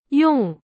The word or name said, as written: 用